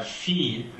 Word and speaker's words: fille: For instance, a word like ville, city, V-I-L-L-E, is pronounced ville with an L. – Right. – But fille, the girl, F-I-L-L-E, which only has one letter difference, is a completely different sound, and you go to the J-glide.